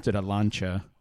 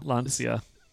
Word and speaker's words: Lancia: We rented a Lancia and Lancia.